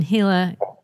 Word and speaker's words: Gila: Well, what I found interesting was I reached out initially about the Gila, the 1910 Gila County Jail. And I still don't know if I'm saying that correctly. – You got it that time.